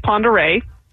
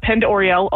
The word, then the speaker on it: Pend Oreille: So our county is Ponderay, but we hear Pend Oriel a lot. That's the only one I've ever heard on the mispronunciation is Pend Oriel, because that's the way it's spelled.